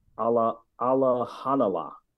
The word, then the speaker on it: Alimkhanuly: So I thought that Janibek, and it's, somehow it's pronounced Alahanala.